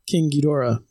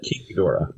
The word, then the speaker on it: King Ghidorah: Which is the proper pronunciation of that? – You know, I thought it was interesting. I mean, the proper is King Ghidorah. But King Ghidorah.